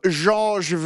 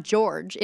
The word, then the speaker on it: Georges: It is in honor of Georges Vezina, who is the goaltender of the Montreal Canadiens from 1910 to 1925, unfortunately. – You actually pronounce that well, because it's not George. It's like George.